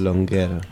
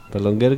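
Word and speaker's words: Perlongher: Néstor Perlonguer. Perlonguer, que se escribe con una... – Una H, H después de la G.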